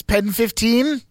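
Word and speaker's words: PEN15: I guess the way you pronounce this is Pen 15.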